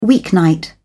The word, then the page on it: weeknight: British English